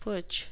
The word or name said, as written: putsch